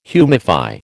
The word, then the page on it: humify: American English